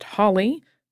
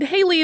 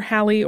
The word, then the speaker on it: Halley: Edmund Halley, you will also sometimes hear it pronounced Holly, sometimes Haley, but Haley is generally considered wrong, and whether Halley or Holly is correct is a matter of some debate. I'm going with Halley.